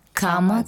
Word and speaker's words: Кама: Произносится «Ом Кама», «Кама» как в Кама-сутра.